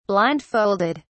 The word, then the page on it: blindfolded: British English